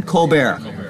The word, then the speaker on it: Colbert: Stephen Colbert, I think is how you pronounce his name, Easter Trump Show. Stephen Colbert.